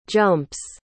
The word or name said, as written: jumps